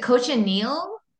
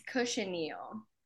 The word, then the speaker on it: cochineal: I assume that's like in a bath kind of scenario when you're soaking the, is it cochineal or cochineal? – it's cochineal. – Cochineal. – That's how Jose pronounced it, was like a, instead of a CH, more of an SH, cochineal.